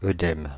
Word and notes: œdème: [ø.dɛm]; Paris

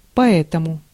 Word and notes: поэтому: /pɐˈɛtəmʊ/

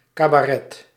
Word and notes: cabaret: /ˌkɑbɑˈrɛ/

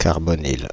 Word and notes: carbonyle: [caʁ.bo.nil]; /kaʁ.bɔ.nil/

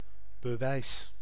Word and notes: prova: /ˈprɔ.va/

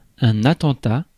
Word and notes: attentat: France; [a.tɑ̃.ta]

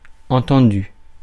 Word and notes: accepted: American English; /æk.ˈsɛp.tɪd/

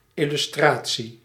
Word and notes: tavla: /ˈtɑːvlˌa/

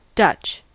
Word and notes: Dutch: /dʌtʃ/

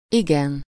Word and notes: igen: /ˈiɡɛn/

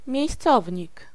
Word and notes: miejscownik: /mʲjɛ̇jsˈʦ̑ɔvʲɲik/

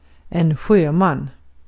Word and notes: sjöman: /ˈɧøːˌman/

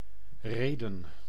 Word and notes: reden: [ɾe.dǝⁿ]; /ˈreːdə(n)/

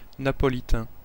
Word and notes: Napolitain: /na.pɔ.li.tɛ̃/

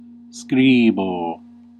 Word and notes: écrire: France; /e.kʁiʁ/

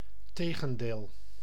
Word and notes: tegendeel: [ˈteː.ɣə(n).deːl]

